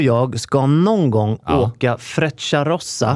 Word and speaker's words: Frecciarossa: Men jag tror att man uttalar Frecciarossa. – Frecciarossa, det känner inte jag till.